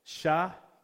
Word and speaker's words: Shavuot: So today is Pentecost, and it's also known by its Hebrew name, is the day of, I'm going to help you pronounce this word, right? Shavuot. So if like you were going to spell it phonetically, it's like S-H-Y-V-A-O-A-T. Shavuot.